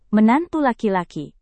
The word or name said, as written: menantu laki-laki